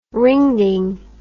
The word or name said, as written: ringding